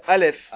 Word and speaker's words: Aleph: Ah non, le Aleph, il a déjà, le Aleph, il a déjà deux syllabes, Aleph.